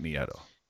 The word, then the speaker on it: Nieto: It's pronounced, if I remember correctly, it's pronounced Long Beach native Matt Nieto.